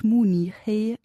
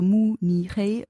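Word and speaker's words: Mu Ni Hei (慕尼黑): Also zum Beispiel die Stadt München heißt auf Chinesisch Mu Ni Hei. Das sind drei Silben, also Mu Ni Hei.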